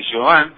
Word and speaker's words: Chauvin: Nicolás Jovin, que así se pronuncia, se escribe Chauvin,